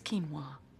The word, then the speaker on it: quinoa: Nipples a quarter inch too low, too much of a gentleman in the boudoir, repeated mispronunciation of quinoa. – It's quinoa.